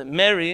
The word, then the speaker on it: Mehri: M E H R I. It's a name. It has an H in it, so you pronounce it if you don't have a good Farsi pronunciation.